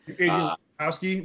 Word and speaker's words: Wojnarowski: Wojnarowski, yeah.